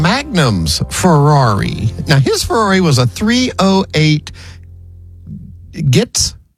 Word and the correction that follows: GTS: Or how do you say that? – How do you say it? – It's GTS. – Oh, yeah. Okay. – Oh, okay. You just say the letters.